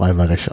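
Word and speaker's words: Alvares: Es darf portugiesisch bei Alvarez mit S am Ende sein, aber gut, wird auch Alvarez ausgesprochen.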